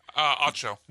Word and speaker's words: Acho: How do you say it? – Acho. – Emmanuel Acho. Mm-hmm. Kind of like the Ocho, but Acho.